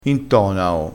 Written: intonao

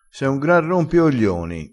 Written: rompioglioni

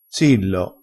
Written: zillo

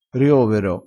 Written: riovero

